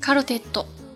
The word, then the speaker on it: カルテット: 那今天呢想跟大家聊一下最近非常受到热议的一部日剧《四重奏》卡罗テッド。卡罗テッド这个单词呢是由意大利语而来的，所以是一个外来语，它是写作片假名的，读作一调词卡罗テッド。